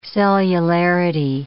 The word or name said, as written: cellularity